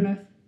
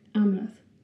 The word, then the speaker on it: Amleth: Amleth. – Say that again for me one more time. – Amleth. – Say it one more time. – Amleth.